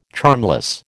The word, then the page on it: charmless: American English